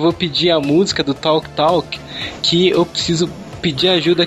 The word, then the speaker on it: Talk Talk: Talk. Talk, quem é? – Talk. – Life's What You Make It, que toca na rádio Flash FM, que eu indiquei aqui. – Ah, tá ótima a pronúncia.